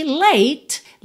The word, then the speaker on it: late: E late, lembra que o som termina no T, não tem esse E aí, tá? O E final está pintadinho de cinza porque não é pronunciado. Então, você pode ter late, late, com esse T, T.